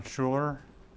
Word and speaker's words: Schuler: Ron Schuler, S-C-H-U-L-E-R.